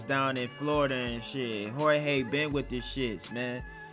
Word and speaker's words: Jorge: And the main event of the evening, fellow Englishman Darren Till versus Horde I said Jorge. My fault, bro. Jorge Masvidal. Masvidal. I don't know why I always say his last name wrong.